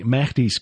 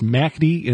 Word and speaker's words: Mahdi: Dr. Timothy Furnish, the author of the two new books, Sects, Lies, and the Caliphate, and 10 Years Captivation with the Mahdi's Camps. I'm going to have to learn to pronounce that correctly. It's Mahdi and not Mahdi.